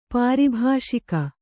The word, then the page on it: ಪಾರಿಭಾಷಿಕ: pāri bhāṣika